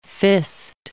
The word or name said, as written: fist